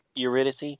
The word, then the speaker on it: Eurydice: Eurydice.